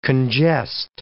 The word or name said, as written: congest